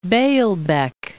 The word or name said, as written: Baalbek